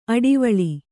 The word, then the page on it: ಅಡುವಳ್ಳ: Kannada